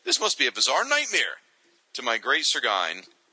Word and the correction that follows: chagrin: Chagrin? – How do you say that? Was that how you spell Chagrin? Oh, sorry. To my great chagrin, I opened the book and I couldn't believe my eyes.